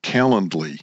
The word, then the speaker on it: Calendly: So that's going to be the next thing, either with the Microsoft product or this Calendly.